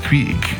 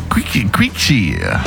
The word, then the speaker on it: Qixi: Quixi, da schreibt man Q-I-X-I. Quixi.